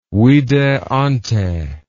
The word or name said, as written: vide ante